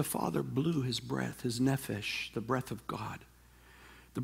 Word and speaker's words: nephesh: There's a wonderful word, it rhymes with refresh in the Hebrew, it's called nephesh.